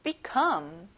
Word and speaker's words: become: come에 강세를 넣어보는 거예요. become.